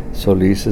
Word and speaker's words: Solis: Emilio, E-M-I-L-I-O, Zamora, Z-A-M-O-R-A. Sometimes I go by Emilio Solis Zamora. Solis is my mother's name, S-O-L-I-S.